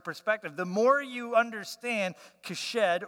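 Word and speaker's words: hesed: So this word, kesed, I know I'm saying with a K, and that's because that's how Hebrews do it. So it's H-E-S-E-D, but pronounced kesed.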